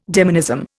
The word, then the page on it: demonism: Indian